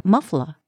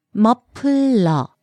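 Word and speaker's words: 머플러: 네, but a lot of times Korean people will also call it a muffler. – Right, what's the Korean pronunciation for that? – It's muffler. Muffler.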